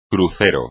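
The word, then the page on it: crucero: español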